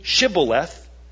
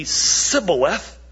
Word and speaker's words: Shibboleth: And if they couldn't say Shibboleth, because they couldn't pronounce it right, but only Sibboleth, then they were dealt with that day, that night.